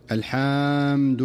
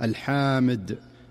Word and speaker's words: الحمد: ومن أمثلة ذلك في قوله تعالى: الحمد لله رب العالمين، فيقول القارئ: الحمد لله رب العالمين، بحيث يمد بعضهم الحاء مدا مفرطا فينطقها الحامد، الحامد لله، وكأنه أضاف ألفا بعد الحاء فيقول الحمد لله رب العالمين على سبيل المثال.